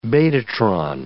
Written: betatron

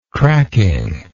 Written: cracking

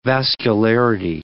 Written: vascularity

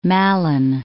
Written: Malin Head

